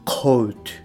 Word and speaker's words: coat: で、同じように、えー、洋服の、まあ、コートなんて言いますけれども、これは、え coat, coat っていうふうにう。っていうふうに発音すると。